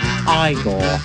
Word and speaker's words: Igor: No, it's pronounced Igor.